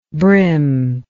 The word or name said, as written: brimmingly